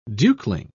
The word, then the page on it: dukeling: British English